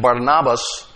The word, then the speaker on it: Barnabas: Now, this man, Joseph, who was surnamed Barnabas, the correct pronunciation, Barnabas, that simply means son of encouragement or consolation.